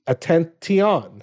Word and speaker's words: attention: It should be attention. – Attention. – Why is T-I-O-N pronounced shun? right?